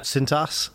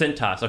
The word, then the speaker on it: Cintas: Cintas. – Is that what it says? Cintas.